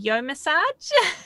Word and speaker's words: Yomassage: Today, I have the privilege of speaking to Michelle Remmer, a remedial massage and certified, I'm probably going to say this wrong, Yomassage. – No, that's correct.